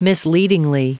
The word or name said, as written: misleadingly